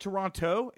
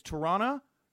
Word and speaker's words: Toronto: And I will say, too, though, the fans in Toronto, which is how I guess it's not Toronto, it's Toronto, where the second T is like two N's, I found out. – Huh. – Yeah, that's how the locals say it. It's like T-U-R-R-A-N-N-O, Toronto is how they say it.